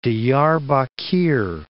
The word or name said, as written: Diyarbakir